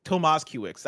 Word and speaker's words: Tomaszkiewicz: Tomaszkiewicz.